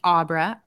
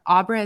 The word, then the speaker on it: Abra: So my website is just Coach Abra. Abra is spelled A, B as in boy, R as in Rachel, A as in Abra.